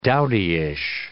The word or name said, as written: dowdyish